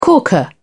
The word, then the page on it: corker: British English